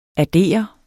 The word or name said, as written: addere